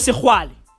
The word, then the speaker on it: Sexwale: This is your granddaughter, bro they were interviewing this dude, uh, uh, Tokyo, Tokyo Sexwale, right? Like his name is spelled like, like this, Sex Wale, okay? Sex Wale. That's his surname, Sexwale. Sex, S E X W A L E, Wale.